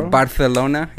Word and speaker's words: Barcelona: I never thought of, like, Spain having projects because, like, you just see, like Madrid or Barcelona or Barcelona, if you want to say it properly.